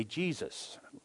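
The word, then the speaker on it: Jesús: And I guess like today, I remember the first time I ever saw somebody with the name Jesus, which is spelled Jesus, J-E-S-U-S, in the English, but pronounced Jesus in Spanish.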